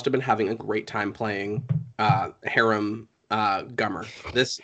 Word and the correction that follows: Hiram: I, am I saying it wrong? Is it harem? – Hiram. – Hiram. Hiram.